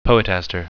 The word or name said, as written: poetaster